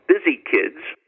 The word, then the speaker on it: Byzi: and it's called Busy Kids, and that's spelled B-Y-Z-I, kids, as in Byzantine kids.